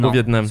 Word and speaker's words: Vietnam: Parce que t'as commencé en disant au Vietnam. – Ce n'est pas tuer des Américains. – Il a même dit le Vietman. Parce qu'il a pas très bien prononcé.